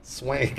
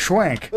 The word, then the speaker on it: Swank: Swank. – Right. Swank.